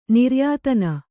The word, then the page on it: ನಿರ್ಯಾತನ: niryātana